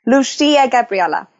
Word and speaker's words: Lucia Gabriela: Lucia Gabriela.